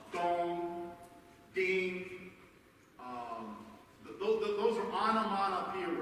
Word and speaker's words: onomatopoeia: It's a word that is an onomatopoeia word.